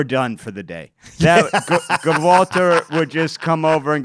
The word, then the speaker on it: Gwalter: His name was actually Walter, but there was a silent G in front of it. And we all pretended not to know that the G was silent, and it would make him really mad because everyone would call him Gawalter.